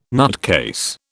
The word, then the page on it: nutcase: British English